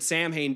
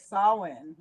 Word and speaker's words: Samhain: Yeah, I thought it was like Samhain. Like, yeah, Samhain. – Like a win at the end. – Yeah, I think that's how it's supposed to be pronounced as it is.